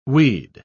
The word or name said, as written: weed